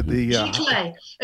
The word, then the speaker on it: Giclée: And you're getting into GK, is that how you say it? With the Giclée. L-E-E.